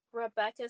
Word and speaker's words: Rebecca: But you also have another, on other social channels, you spell it C-O-F-F-E-Y. So exactly. Where can people reach you? – Okay. So my LinkedIn is Rebecca Sarah Coffey. I'll spell that R-E-B-E-C-C-A Sarah S-A-R-A Coffey C-O-F-F-Y.